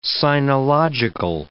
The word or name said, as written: sinological